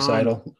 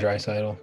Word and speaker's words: Draisaitl: Is it Leon? – Dreisaitl. – Yeah. There we go. – Leon right. Dreisaitl.